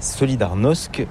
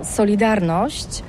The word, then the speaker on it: Solidarność: Alors solidarité, en français on dit solidarnosc, mais ici à Gdansk on ne prononce pas solidarnosc. – On solidarność.